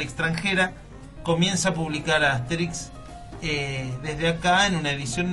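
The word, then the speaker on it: Astérix: Dice, lo importante es que Asterix, con acento en la E, se vuelva a publicar en Argentina.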